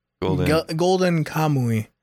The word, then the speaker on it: Golden Kamuy: Golden Kamuy.